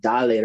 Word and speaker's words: Dale: And I told her, I was like, no, it's pronounced Dale Real. Kind of like what Pitbull says.